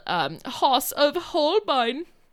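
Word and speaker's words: Haus of Holbein: And then we have a fun song that they all sing together called Hoss of Holbein. That's how they say it.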